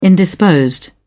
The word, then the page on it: indisposed: British English